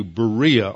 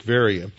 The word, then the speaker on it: Veria: And then um, that night, the brethren sent Paul and Silas away uh, and they go from there to Berea or Veria, as it's pronounced in Greek, Veria.